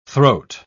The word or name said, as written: throat